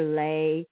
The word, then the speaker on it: lei: The L-E-I, that is pronounced lei.